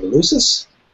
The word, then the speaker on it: Eleusis: Eleusis.